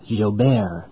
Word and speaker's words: Gilbert: Meanwhile, the National Weather Service is keeping an eye on Tropical Storm Gilbert, or perhaps that's pronounced Gilbert.